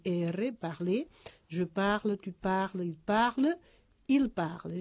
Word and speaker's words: parles: Es decir, la primera, segunda, tercera persona singular y la tercera, el plural, se pronuncian en el caso de esos verbos regulares igual. Je parle, tu parles, il parle.